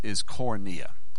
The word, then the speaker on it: koinonia: But the word is koinonia. Koinonia. Let me read it to you and spell it for you. That's K-O-I. K-O-I-N-O-N-I-A. Koinonia. See if I got that spelled right.